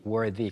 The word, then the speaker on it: worthy: 훌륭한 또는 자격이 있는을 가리키는 worthy. 그냥 y가 빠진 형태는 worth the 발음인데 y가 붙으면서 the 발음이 되어서 worthy가 되고요.